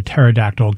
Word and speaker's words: pterodactyl: Why can't you hear a pterodactyl going to the bathroom? Because the P is silent.